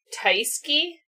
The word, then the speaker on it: Tyskie: It is a Polish beer, Tyski. That's what I'm going to pronounce it as. – How do you spell it? – T-Y-S-K-I-E.